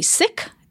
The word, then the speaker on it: Sikh: The proper pronunciation is actually Sikh. S-I-K-H.